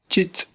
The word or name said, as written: chitz'